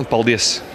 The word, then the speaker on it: paldies: En letón, paldies.